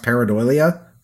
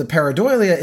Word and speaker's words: pareidolia: Like you talk, have you ever heard of a phenomenon called, I think it's pronounced pareidolia or pareidoia?